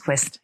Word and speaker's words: Qvest: Det du værktøj, som du har udviklet, og det er Quest, eller Q-V-E-S-T. – Ja, man udtales Quest.